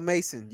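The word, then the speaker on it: Masson: If you drinking Irken jerk up Paul Mason, you need to make life changes, bro. Mm. – Nah, I call it, pronounce the name right. – Mason. – Mason, don't try to make that sound fancy.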